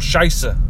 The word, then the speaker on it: Scheiße: If you ever come across any native German speakers, you might soon discover that the German for shite, the Scheiß or Scheiße, which doesn't sound too different from the Scottish pronunciation of shite.